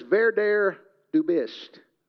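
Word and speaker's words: Werde, der du bist: The way you pronounce it in German is Werder du bist.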